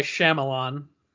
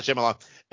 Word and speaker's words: Shyamalan: How do you say his name? – I'm pretty sure it's just M. Night Shyamalan. – There you go. That's what I thought. So M. Night Shyamalan.